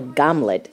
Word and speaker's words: Hamlet: I meet Hamlet Zinkivsky, or Gamlet, as the name is pronounced in Ukrainian, on a windy March day in his native city of Kharkiv.